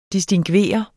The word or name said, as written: distingveret